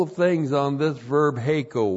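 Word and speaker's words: Heiko (ἥκω): And then we have the verb heiko. H, long E, K, long O. Heiko.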